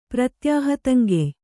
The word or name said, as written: ಪ್ರತ್ಯಾಹತಂಗೆಯ್